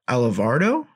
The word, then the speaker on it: Alvarado: Alvarado is how you pronounce it. I'm pretty sure. – Mm-hmm. – But nevertheless, like that dude's got some nasty stuff. – Alvarado, yeah.